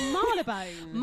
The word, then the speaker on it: Marylebone: The Merrylebone? – It's Marylebone.